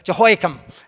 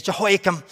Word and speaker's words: Jehoiakim: If you look at Jeremiah chapter 36, as we're just moving towards that, a king, Jehoiakim, however you say his name properly, Jehoiakim, that's how you actually say it, is Jehoiakim.